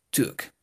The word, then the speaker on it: took: Eso, took, pero se pronuncia como si fuera una O. Took, Tuk.